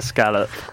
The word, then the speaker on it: escalope: The Great Escalope! Oh, no! I knew it was wrong! – It's a scallop. – Escalope. Is that how you say it? – Scallop.